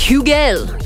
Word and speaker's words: Hugel: Is it Hugel? Apparently, it's Hugel. You have to put on the French accent for it.